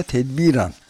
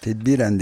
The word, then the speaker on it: tedbiren: Bu ciddi bir şimdi Cumhuriyet Halk Partisi Genel Başkanlığı görevine mahkeme kararıyla tedbiren, hep tedbiren deniyor ama sanıyorum tedbiren diye telaffuz edilmesi doğru olur.